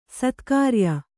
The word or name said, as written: ಸತ್ಕ್ರಿಯೆ